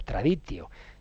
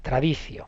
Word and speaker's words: traditio: Eh, y a esa acción de transmitir tradere es lo que se llama tradición, tradicio o traditio, según la, manera de pronunciar el latín que es discutible como se haga me gusta más la, manera italiana, tradicio.